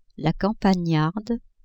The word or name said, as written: campagnarde